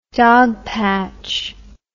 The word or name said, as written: dogpatch